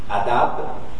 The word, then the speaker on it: adab: Here he starts with that, and he will play with the word, and adab rhymes with rab.